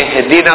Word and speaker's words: اهدنا: فلما نقول البعض في اهدنا الصراط المستقيم يقول اهدنا اهدنا، قراءة خاطئة، يعني يحاول يبين انها همزة وصل مفروض تقطعها اهدنا الصراط المستقيم. اذا بدأت بها اهدنا الصراط المستقيم تنطقها مثل ما تنطق الهمزة العادية.